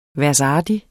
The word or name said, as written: værsartig